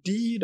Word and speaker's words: Die: Und dann hat er gemeint, ach, ja, seine Mutter, die wohnt jetzt in Die, das ist so ein kleines Dorf, das wird, also das spricht man wie der, der, die, das, ne, die.